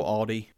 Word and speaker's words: Aldi: At the local Aldi. – Okay. For those of you not from Virginia, that word has an L in it. It's Aldi.